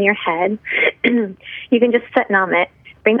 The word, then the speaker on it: Sat Nam: One of my favorite chants is Sat Nam, pronounced just like, but mom.